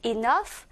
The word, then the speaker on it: enough: gh 이두 개의 사운드가 합쳐지면 마치 f처럼 사운드가 나게 되죠. 그래서 enough